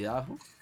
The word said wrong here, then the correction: Idaho: ¿Y ¿Cómo se dice? – Duncan Idaho. Eso, Idaho, gracias.